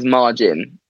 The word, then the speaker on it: Majin: I don't know how they're pronounced. Like, M-A-J-I-N? – Yeah, Adam told me it was Margin.